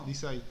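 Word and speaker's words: Deicide: Decide, sí. Wow. – Pensé que tenía un nombre más. Más, más, impronunciable. Porque. No sé. – Porque uno Está, acostumbrado a, hablarlo en, uruguayo. Claro, sí, sí. Y después se pronuncia nada que ver.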